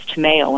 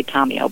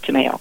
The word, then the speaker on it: Tomeo: The correct Italian pronunciation is Tomeo. Unfortunately, they've Americanized it, and my family has adapted to that, so they say Tomeo. But actually, it sounds much prettier the correct Italian way, Tomeo. – T-O-M-E-O.